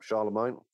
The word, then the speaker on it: Charlemagne: He claims that the Holy Roman Emperor, I really don't know how to pronounce this name, Holy Roman Emperor Charlemagne, is it? – Yep, Charlemagne.